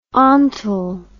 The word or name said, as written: ontal